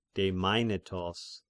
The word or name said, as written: Demainetos